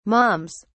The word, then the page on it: moms: British English